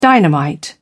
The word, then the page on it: dynamite: British English